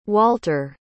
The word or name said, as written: walter